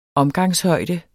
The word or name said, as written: omgangshøjde